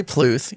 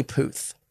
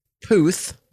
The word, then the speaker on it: Puth: It's not Charlie Pluth. You have a problem with pronunciation. It's Charlie Puth. There's no it's Pluth. – It's Puth.